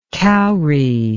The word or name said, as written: kaury